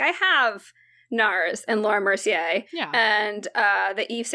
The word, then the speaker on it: Laura Mercier: Is it Laura Mercier? – Yes. – Yes.